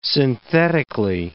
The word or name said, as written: synthetically